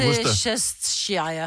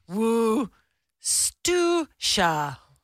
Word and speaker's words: Worcestershire: How is Worcestershire actually pronounced? It's pronounced Worcestershire.